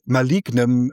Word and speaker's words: malignen: oder ist malin auch noch wieder eine Steigerung zu dem pathologischen Narzissmus? – Sie sprechen von dem malignen Narzissmus. – Ich habe es falsch ausgesprochen, malignem, ja, malignem, ja, okay, malignem.